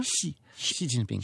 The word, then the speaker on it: Xi Jinping: J'ai dit Xi Jinping, mais c'est pas le bon. J'ai mal dit ça là. – Faut dire Xi. Mais écoute, c'est pas très grave. On parle français et il y a des sons en chinois qui sont difficiles à faire en français. – Comment tu le prononces encore? – Xi Jinping. – Xi, mais avec en s'étirant un peu les commissures.